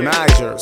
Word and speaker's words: Niger: Come on, let me take you to a river, Niger. Soft G like a J in pajama.